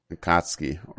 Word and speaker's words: Akatsuki: Akatsuki organization, who wishes to capture the nine-tailed beast.